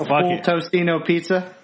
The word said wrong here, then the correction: Totino's: Well, I'm so fat that I say it right. It's Totino's.